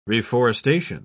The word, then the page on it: reforestation: British English